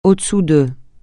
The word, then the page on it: au-dessous de: francais